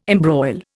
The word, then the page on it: embroil: Indian